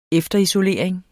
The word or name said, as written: efterisolering